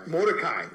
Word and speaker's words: Mordecai: Mordecai.